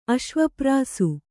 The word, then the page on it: ಅಶ್ವಪ್ರಾಸು: Kannada